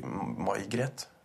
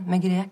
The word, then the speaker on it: Megret: Unnskyld, eh, så er ikke det Uttales det May-Gret? – Nei, det, er et fransk navn. Megret. Grete Megret.